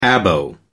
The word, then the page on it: abo: American English